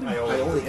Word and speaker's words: aioli: Fried calamari with harissa aioli. How did I say it? Aioli? – Aioli. Is that this? – Yes, yeah, that's how we say it.